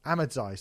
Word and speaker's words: Ahmadzaï: Even by dance producer standards, Am- Amadzai, Ahmadzaï, sorry, I'm always saying his name wrong.